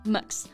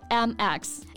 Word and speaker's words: Mx: 嗯，M X。Max。不过这个 title 的读音呢，也会有点让人疑惑啊。Most people pronounce it as Max with a sort of schwa sound，就中间加了一个 schwa 的音啊。But actually, a lot of people just spell it out like MX.